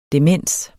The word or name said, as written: demens